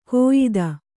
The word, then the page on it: ಕೋಯಿದ: kōyida